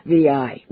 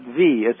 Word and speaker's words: Vi: It's not pronounced Vi, it's pronounced V, and it's just a crazy name for a very luxurious, large retirement home in Palo Alto. And I don't know where they got the name, but it's pronounced V as in the letter V.